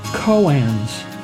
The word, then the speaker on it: koan: Koans are one of the chief cornerstones of Zen Buddhism. K-O-A-N is pronounced Koan.